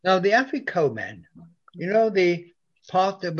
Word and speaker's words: Afikomen: Afikomen. A-F-I-K-O-M-E-N.